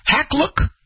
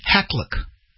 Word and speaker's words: Hakluyt: Meet London-based Hackluck and Company. Let me spell it for you so you can look it up. H-A-K-L-U-Y-T, Hackluck.